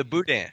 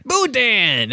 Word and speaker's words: boudin: Oh, the boudin. – Boudin! That's right. You guys don't have boudin? – No. It's boudin. It's boudin. Not boudin. It's boudin. Boudin. You gotta draw it out.